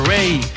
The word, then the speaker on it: Raye: hermosa Ray. Se escribe Raye, R-A-Y-E, con esta canción que se llama I Know You're Hurting.